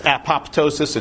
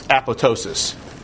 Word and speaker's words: apoptosis: This has to do with what is called apoptosis, an often mispronounced word. It looks like it's apoptosis, it's apoptosis. And apoptosis is programmed cell death.